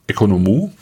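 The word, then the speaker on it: Oikonomou: Von Gianni Economou. Also dieses, genau, der Name wird Economou ausgesprochen. Und der... – Moment, Oikonomoi wird Economou ausgesprochen? – Ja, würde ich sagen.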